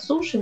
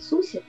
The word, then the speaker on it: суси: То есть хотя бы те же суси, ну, русские говорят суши, но более правильно говорить суси, они, ну, вот в Москве могут быть с майонезом.